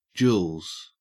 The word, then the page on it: jewels: Australian English